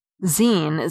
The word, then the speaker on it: zine: And fridge, short for refrigerator, the F-R-I-G spelling was popular in the 1940s and 1950s, until it was quite properly supplanted by F-R-I-D-G-E. Zine, Z-I-N-E, short for magazine, would be expected to be pronounced to rhyme with sign, but it can be excused because it follows the spelling and pronunciation of the longer form. Mic, M-I-C, is doubly problematic because English simply doesn't have a robust tradition of words ending in C.